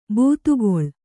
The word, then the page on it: ಬಾತುಗಾಲು: Kannada